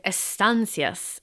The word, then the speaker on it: Estancias: We're going to listen to Estancias, as the lovely man on Forvo encouraged me to pronounce it.